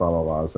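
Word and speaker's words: Allah-Las: The spelling of Alalaz is like the Allah is like the god Allah. And and Loss is, who knows, but it's Allah-Loss and pronounced Allah-Loss, and it's excellent.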